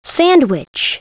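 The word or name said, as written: sandwich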